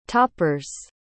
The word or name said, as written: toppers